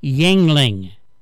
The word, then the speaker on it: Yuengling: It's Yuengling, Yuengling, and it's pronounced Yuengling, Yuengling beer made in Pottsville, Pennsylvania.